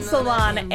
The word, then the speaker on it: Salaun: Guys, I'm Lauren Salon, and you can find me everywhere online at Lauren Salon. That last name is spelled S-A-L-A-U-N, and I'm joined tonight by Whitney.